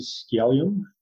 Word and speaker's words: Skjellum: Here I am again, Charles Eisenstein, this time joining you with Eivind Skjellum. – Did I say that right? – Let's see. Uh, yes, it's good enough.